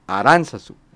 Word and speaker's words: Aránzazu: que hay que decirlo, no lleva el acento en la U, lo lleva en la segunda A, Aranzazú, no Aranzazú.